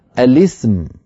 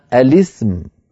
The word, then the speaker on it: الاسم: لذلك فإنهم يكسرون لام التعريف للتخلص من التقاء الساكنين فيقولون الاسم، فيقولون الاسم يعني بلام مكسورة.